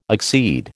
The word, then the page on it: accede: American English